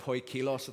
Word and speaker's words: poikilos: It's this word, poikilos, I think is how you pronounce it, but it means this, wrought in various colors.